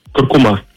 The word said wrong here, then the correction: куркума: Несмотря на то, что тоже большинство произносит данное слово с ударением на последний слог, акцент в нем все же стоит на втором. Правильно говорить куркума, представляете?